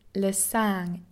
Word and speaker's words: sang: Blood is le sang in French, but I can never manage that pest of a nasal vowel right.